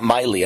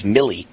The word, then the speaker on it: Milley: I think it's Milley, not Miley. I think it's Milley.